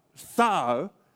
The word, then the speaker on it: czar: The Romans, they used that word Sar, and they pronounced, well, they spell it differently, C-Z-A-R, Sar, and they used that word, and then eventually that word became the word Caesar, like Julius Caesar.